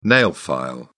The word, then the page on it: nail file: British English